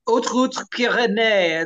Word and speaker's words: Haute Route Pyrénées: The HRP is the Haute Route Pyrénées. It's the Pyrenees High Route. Haute spelled with an H. It's a French pronunciation there.